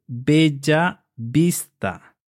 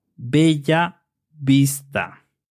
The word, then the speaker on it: Bella Vista: The double L is pronounced ya. So we would say Bella Vista. Bella means beautiful. And vista is sight. So it translates as beautiful sight. Bella Vista.